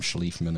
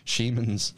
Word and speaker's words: Schliemann: Schliemann. I think I butchered it, called him Schliefman or something.